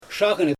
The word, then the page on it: ʃaaɣɯn: Xyzyl